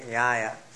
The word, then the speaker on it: Nyaya: N-Y-A-N-A, long A, Nyaya.